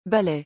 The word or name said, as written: balai